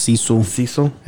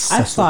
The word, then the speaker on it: Cecil: It's not pronounced Cecil. It's actually pronounced Cecil.